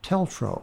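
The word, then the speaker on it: TLTRO: You've heard the acronym just now, but it's pronounced TELTRO.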